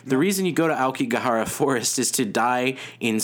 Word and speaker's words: Aokigahara: Aokigahara.